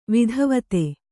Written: ವೇದವೇತ್ತ

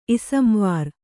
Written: ಇಸಂವಾರ್

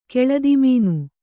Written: ಕೆಳದಿಮೀನು

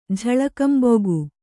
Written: ಝಳಕಂಬೊಗು

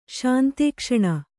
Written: ಶಾಂತೇಕ್ಷಣ

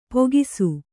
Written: ಪೋಗಿಸು

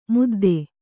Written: ಮುದ್ದ

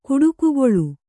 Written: ಕುಟುಕುಗೊಳ್

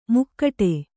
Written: ಮಕುಟ

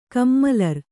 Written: ಕಮ್ಮಲರ್

